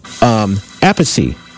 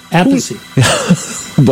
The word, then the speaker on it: Appice: And all these years, I think I've been saying Appice as the rest of the world, but apparently just found out it's actually pronounced Appice. – Appice.